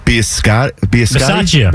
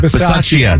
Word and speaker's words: Bisaccia: I'm going to butcher this name, but Rich Bisaccia. – Bisaccia.